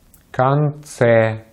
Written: конце

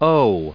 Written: owe

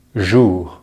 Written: jour

